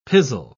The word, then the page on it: pizzle: British English